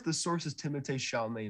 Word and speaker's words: Timothée: And that is how you pronounce it. Timothée is how you're supposed to say it. Not Timothy. We've been wrong.